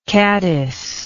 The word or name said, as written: caddised